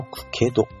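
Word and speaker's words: 加賀の潜戸: あ、加賀のクケド、これでクケドって読むんだ、聞いたことない加賀のクケド、タコの七つ穴、タコバナなど、島根半島宍道湖中海ジオパークの上空を約 30 分かけて遊覧するコースです。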